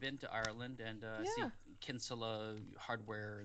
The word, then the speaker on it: Kinsella: And you may be able to tell from my surname that it's Irish, so it's pronounced Kinsella in Ireland. – Oh. – But over here, yeah, we do say Kinsella over here.